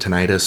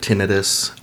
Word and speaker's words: tinnitus: So it varies by region. Some people pronounce it tinnitus. Some people pronounce it tinnitus. Either is an acceptable form.